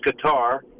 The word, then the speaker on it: Qatar: Qatar has been known, and the correct pronunciation is Qatar, unlike what Matt...